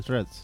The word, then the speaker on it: Threads: La A no suena, Threads.